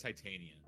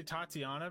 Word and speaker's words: Titania: and then the ending was, how do you pronounce her name? Titania? It's Titania. – Yeah, I think so. Because I keep on wanting to say Tatiana because that's...